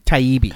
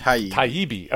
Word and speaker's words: Taibbi: Is that how you pronounce his last name? – Taibbi. – Taibbi. Okay, cool.